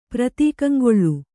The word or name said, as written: ಪ್ರತೀಕಂಗೊಳ್ಳು